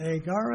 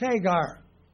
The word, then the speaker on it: Hagar: So it's not Agar, it's Hagar.